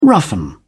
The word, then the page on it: roughen: British English